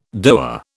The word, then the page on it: DOA: British English